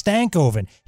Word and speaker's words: Stankoven: I put I put in parentheses stank as in crank. Oh. And then and then I pronounce the back half. So it's Stankoven.